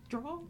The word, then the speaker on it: drawl: And if you are listening in, you probably hear our southern drawl. – Yeah. – Is that how you say it? Drawl? – Drawl. Not like draw, cool. Like D-R-A-W, like you're drawing.